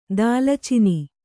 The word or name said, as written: ದಾಲಚಿನಿ